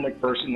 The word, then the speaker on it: McPherson: Hello, my name is Kevin McPherson. That's spelled M-C-P like Paul, H-E-R-S-O-N.